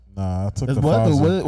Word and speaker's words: Pfizer: The Pfizer, the P is silent.